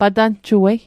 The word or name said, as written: patanjuei